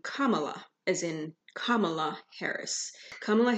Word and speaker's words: Kamala: The name Kamala, pronounced Kamala as in comma dash la, is of Sanskrit origin.